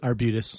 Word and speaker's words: Arbutus: Arbutus. That's the Latin name for Madrone, actually.